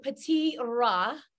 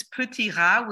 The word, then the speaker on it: Petit Rat: It is called Petit Rat. I tried my best. It is spelled like Petite Rat, but hi. – Thank you. And that was pretty good.